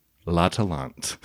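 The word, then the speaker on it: L'Atalante: Jean Vigo's La Talente.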